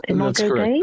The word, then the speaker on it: Imago Dei: Prompted by Father Toby's note earlier saying that, you're studying, and I don't quite know how to pronounce this, is it Imago Dei? How do you say it? Imago Dei? That's correct. Imago Dei, yeah.